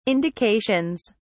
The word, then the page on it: indications: British English